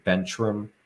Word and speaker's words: Bentrim: It's Brandon Bentrim. I tell people to remember my last name as if you were riding a bike and you hit the curb and you bent the rim.